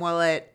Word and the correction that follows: Wilmette: eventually or originally started. Did I say his name wrong? – I think it's Wilmette. – Wilmette, sorry. started a band originally with his brother playing blues music, and it does have a bit of that blues feel, the bass.